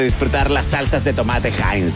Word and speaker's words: Heinz: Sí, vamos a hablarles de Heinz. Así se pronuncia, mi gente querida, por si acaso. Heinz.